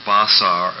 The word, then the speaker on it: basar: We see a Strong's word 1319, which is spelled B-A-S-A-R, maybe pronounced Basar or Basar,